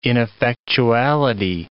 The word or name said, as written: ineffectuality